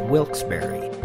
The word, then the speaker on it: Wilkes-Barre: The Bat Tat News Desk has now learned the proper pronunciation is Wilkes-Barre.